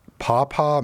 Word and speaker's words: Paw Paw: We move on to Jacob in Paw Paw, Michigan.